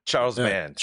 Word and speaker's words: Charles Band: I believe Charles Band, did I say his name right? – Yeah, Charles Band.